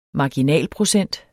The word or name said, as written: marginalprocent